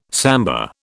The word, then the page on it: samba: British English